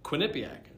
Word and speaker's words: Quinnipiac: She's a nutmegger who went to UNC Chapel Hill and Quinnipiac.